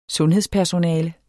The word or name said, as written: sundhedspersonale